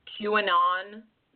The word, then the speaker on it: QAnon: QAnon? – QAnon.